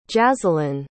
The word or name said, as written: jazzalyn